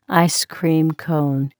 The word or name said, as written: ice cream cone